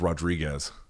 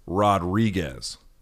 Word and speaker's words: Rodriguez: So if you grew up speaking English and you see this word, the way you would pronounce it is Rodriguez, right? Most people. Rodriguez. Rodriguez. Rodriguez. But the way it was taught to me in Espanol is Rodriguez. It's R-O-D-R. That's one. Then D-R-I. Then G-U-E-Z.